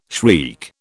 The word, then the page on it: shriek: British English